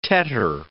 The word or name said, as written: tetter